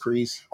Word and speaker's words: Kryze: So it's not Kryze, it's Kryze.